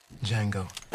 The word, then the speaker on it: Django: Django. D-J-A-N-G-O. The D is silent. Django!